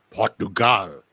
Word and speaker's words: Portugal: Portugal, or as my Portuguese friends will pronounce it, Portugal.